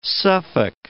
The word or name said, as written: Suffolk